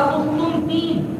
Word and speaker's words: قسطنطین: جس کا عربی میں تلفظ ہوگا قسطنطین, اسی کے نام سے قسطنطنیہ پڑا جو آج استنبول کے نام سے, یہ ترکی کا غالباً دار سلطنت ہے.